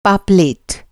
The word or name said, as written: पापलेट